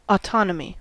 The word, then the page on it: autonomy: American English